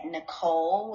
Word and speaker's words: Nikol: I can be reached on Instagram at Nicole, which is spelled Nikol underscore Alicia, spelled Alecia.